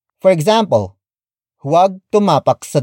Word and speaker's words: tumapak: Which is spelled as H-U-W-A-G. It means do not. Up next, we have tumapak. Tumapak. Tumapak. Tumapak is spelled as T-U-M-A-P-A-K.